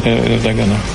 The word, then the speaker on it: Ердоган: Слово президенту Турецкой Республики господину Таїпу Ерджагану. Реджепу Таїпу Ердогану.